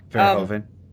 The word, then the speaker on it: Verhoeven: Is that how you pronounce the director's name? – Yeah, Verhoeven.